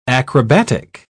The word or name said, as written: acrobatic